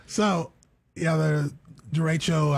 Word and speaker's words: derecho: Yeah, I'm pretty sure it's derecho.